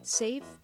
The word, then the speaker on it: Saiv: Save Boutique, that's spelled S-A-I-V, is a great place for clothes, accessories, and shoes.